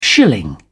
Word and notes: shilling: British English

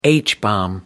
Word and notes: h-bomb: American English

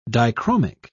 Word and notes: dichromic: British English

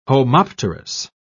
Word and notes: homopterous: British English

